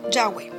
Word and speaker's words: Yahweh: Las transcripciones griegas indican que YHWH debería de ser pronunciado Yahweh.